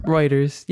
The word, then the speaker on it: Reuters: It's Reuters? Reuters? – Reuters. Yep, you got okay.